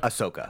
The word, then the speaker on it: Ahsoka: Am I saying it right? Ahsoka? – Ahsoka. – Ahsoka. – Yeah.